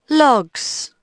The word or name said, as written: logs